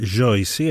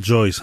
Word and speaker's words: Joyce: Η συνεργασία με την Joyce, έτσι προφέρεται στα βραζιλιάνικα πορτογαλικά, Joyce αν θέλετε στο αγγλικό,